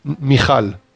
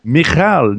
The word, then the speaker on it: Michal: And now Michael, is that how you pronounce it? Michael? – Michal. – Michal.